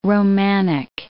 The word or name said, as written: Romanic